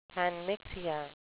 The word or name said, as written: panmixia